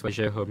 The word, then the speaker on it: Vallejo: My Instagram handle is Alex Vallejo Music, VALLEJO, because no one knows how to spell my surname whenever I pronounce it.